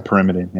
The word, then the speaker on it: pyrimidine: Pyrimidine. Is that how you say it? – Yeah, pyrimidine. Yeah.